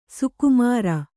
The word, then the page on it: ಸೊಕ್ಕುಮುರಿ: kannada